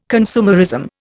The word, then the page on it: consumerism: Indian